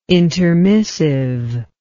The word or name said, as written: intermissive